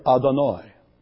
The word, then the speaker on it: Adonai: The text in the Hebrew reads Yahweh, but never is that pronounced. It is always pronounced with a substitute word, Adonai.